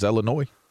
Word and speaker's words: Illinois: And I would just, for the longest time, be like, it's Illinois. It's not Illinois. – Oh, it's like Des Moines.